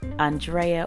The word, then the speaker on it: Andrea: I'm the alert, ever-present host, Andrea, or Drea if you butcher the A-N portion of my name.